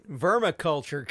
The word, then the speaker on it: Vermiculture: You can have all the worms you need with Vernon's Vermicular Kit. Everything you... – Vermiculture Kit.